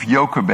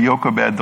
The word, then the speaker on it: Jochebed: Today, we're on a woman by the name of Jochebed, and I should say her name starts with a J. But in Dutch, they pronounce the J with a Y, and I simply cannot get that out of my head. So you can all think of J if you want.